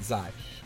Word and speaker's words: Xy: XY is the way it's spelled, but he pronounces it Zai.